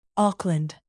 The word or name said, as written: auckland